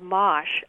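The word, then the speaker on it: mâche: Well, our last caller mentioned mosh. That's spelled M-A-C-H-E.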